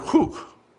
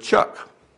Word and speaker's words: chuk: The Hebrew word chuk is pronounced like the word hook, so it's probably hook, cook. Not like the word chuck.